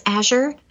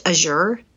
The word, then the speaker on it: Azure: Azure, because for some reason I always thought it was pronounced Azure, but I guess that's more of a European pronunciation.